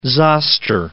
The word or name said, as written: zoster